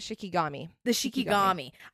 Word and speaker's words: shikigami: The Shikigami.